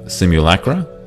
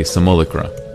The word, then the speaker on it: simulacra: So you can say simulacra, or some people say simulacra, or simulacra, or simulacra. So either one is fine.